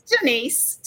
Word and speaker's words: Jeanise: And let me make sure say your name for me. I forgot to practice before I got on here. Say it's Jeanise, just like Jeanise.